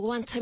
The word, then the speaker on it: want: And make sure you pronounce the T in want. – What do you want me to tell him? – Dile lo que sea.